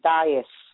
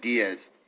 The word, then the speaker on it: Dias: the Portuguese people pronounce it Dias. They don't pronounce it Diaz.